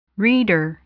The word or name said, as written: reader